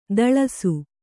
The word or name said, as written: ದಳಸು